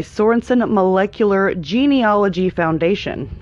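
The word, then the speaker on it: Sorenson Molecular Genealogy Foundation: I butchered that. Let's try this again. In 2001, GeneTree was purchased by Sorensen Molecular Genealogy Foundation. I did it right that time.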